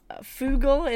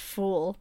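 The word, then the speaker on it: Fugl: Another game that I've played is called Fugle, is how a lot of people pronounce it. I've been told by the developers it's pronounced Fool.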